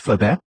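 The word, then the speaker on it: Flobert: Louis Nicolas Flaubert, pronounced Flaubert, made the first commercially successful rimfire cartridge in 1845, the 6mm Flaubert.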